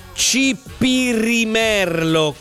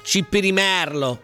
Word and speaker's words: cipirimerlo: Mi sto frustando da solo perché allora si dice cipirimerlo con la R, quindi complimenti a chi diceva che si diceva appunto con la C, con l'accento sulla penultima sillaba, va bene. La parola si pronuncia con la E aperta, cipirimerlo, merlo.